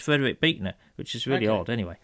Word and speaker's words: Buechner: Then I was thinking about what Nancy's, that quote from Frederick Buechner, it's pronounced. – Buechner, is it? – Yeah, apparently.